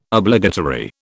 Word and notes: Obligatory: British English

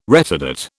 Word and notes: retardate: British English

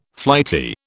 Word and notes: flighty: British English

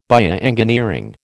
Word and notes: bioengineering: American English

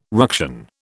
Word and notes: ruction: British English

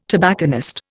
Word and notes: tobacconist: Indian

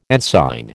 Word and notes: ensign: American English

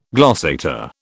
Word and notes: glossator: British English

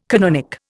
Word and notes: canonic: Indian

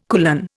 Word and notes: kulan: Indian

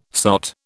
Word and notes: sot: British English

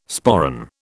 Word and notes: sporran: British English

